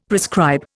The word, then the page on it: prescribe: Indian